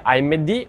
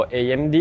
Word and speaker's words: AMD: AMD o AMD, eh, così poi non mi cazziano per la pronuncia, avete entrambe le opzioni,